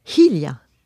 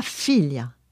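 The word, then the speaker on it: hilha: La fille, c'est Hilia. Hilia, et pas filia. Pas un F, mais un H.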